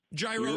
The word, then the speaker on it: gyro: Gyro, Euro. Yeah. I say Euro. Enough people say gyro. I feel like it's fine either way.